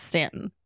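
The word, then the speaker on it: Staunton: It's spelled like it's going to be pronounced on, but they very much tell you it's Stanton. They will correct you quick, fast, and in a hurry.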